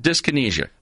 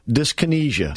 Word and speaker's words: dyskinesia: Dyskinesia. – Dyskinesia.